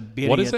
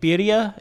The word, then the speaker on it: birria: Yeah, the birria taco. – What's it called? – Birria. It's like, it's spelled B-I-R-R-I-A.